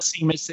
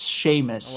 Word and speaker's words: Seamus: Seamus Finnegan. – Yes. – No, that is incorrect. – Oh, no, the other one. – And it's not Seamus, it's Seamus.